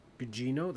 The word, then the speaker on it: Gugino: Carla Gugino. Yes. Did I say that right? Gugino? That's how I say it.